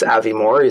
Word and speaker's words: Avimor: It's Avimor. You spell it A-V-I-M-O-R.